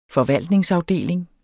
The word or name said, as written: forvaltningsafdeling